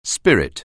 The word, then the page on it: spirit: anglais